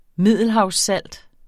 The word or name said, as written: MIDDELHAVSSALT